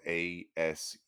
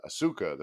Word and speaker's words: Asuka: Like, I'm trying to think of speaking of a pro wrestler, but like, Asuka from the WWE, her name is spelled Asuka. I read that as Asuka the first time I ever heard of it. It's pronounced Asuka. – Yeah. – U is just, we just pretend there's no U.